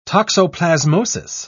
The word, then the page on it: toxoplasmosis: British English